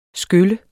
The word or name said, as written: skyl